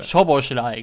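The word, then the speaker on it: Szoboszlai: Und es gibt noch einen Spieler, der heißt, ist einer der ungarischen Stars im Mittelfeld, der heißt ungefähr Schobelschei. Oder ich kann es nicht genauer aussprechen. Schoboschlei. Schob Schoboschlei. Schoboschlei, genau.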